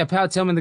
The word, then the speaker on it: Tillman: Am I saying that right? Yeah, Pat Tillman, the guy that died.